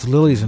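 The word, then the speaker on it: Les Ulis: You know, I tried looking up how to pronounce that. It's Lulis in French. Did I say Les? It's Les.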